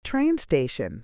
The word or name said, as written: train station